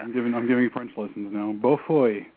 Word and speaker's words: Beaufoy: I'm butchering his name, but it's Simon Beaufoy. It's B-E-A-U-F-O-W. How would you? – Beaufoy.